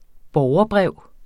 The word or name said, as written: borgerbrev